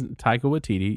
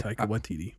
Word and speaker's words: Waititi: Taika Waititi? Is that how you pronounce it? That is Taika Waititi, yeah. I'll be right back. – Taika Waititi.